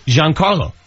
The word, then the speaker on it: Giancarlo: Giancarlo? – No, it's not Gian. – Gian. – What is it? – Giancarlo.